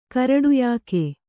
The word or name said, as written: ಕಿರೀಟಯೋಗ